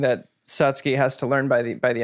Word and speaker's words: Satsuki: And I think Satsuki is the like correct pronunciation.